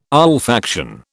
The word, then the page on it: olfaction: British English